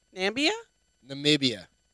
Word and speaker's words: Namibia: They are found in, I'm going to say this wrong, Namibia? – Oh, boy, you said. Are swallowing cobras better? Probably, bro. – Namibia. There you go.